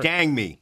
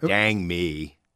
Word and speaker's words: Dang Me: It's Dang Me. – Dang Me. – Not Dang Me. – Oh, so I'm putting the emphasis on the wrong syllable.